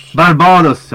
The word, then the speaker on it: Barbados: Barbados, 71 personer. Förlåt. Var det någon fel uttal? – Barbados, ja.